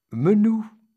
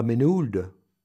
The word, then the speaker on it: Menehould: Menou, c'est pas Menehould.